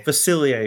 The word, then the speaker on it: Facilier: How do I say that? I actually haven't seen the movie. I'm going to Facilier. – Excuse me. I guess it's, you know, French or Cajun.